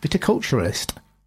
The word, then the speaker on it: viticulturist: who is a viticulturist. I think I've said that right. – Yes.